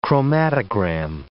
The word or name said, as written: chromatogram